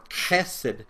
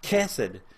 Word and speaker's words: chesed: It's hesed. If you pronounce it correctly, it begins with a guttural. It's more chesed.